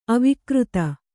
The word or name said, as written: ಅವಿಕೃತಿ